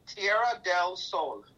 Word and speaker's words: Tierra del Sol: I'm saying it wrong. How do you say it? – Tierra del Sol.